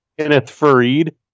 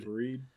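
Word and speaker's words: Kenneth Faried: They just signed the Ferriat, I think is how you say it. How do you say it? – I don't know. – Dude, how do you say that? – Kenneth Fareed.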